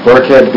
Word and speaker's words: Burkhead: Yeah, Burkhead, B-U-R-K-H-E-A-D.